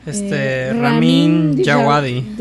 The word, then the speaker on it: Ramin Djawadi: Ramin Djawadi.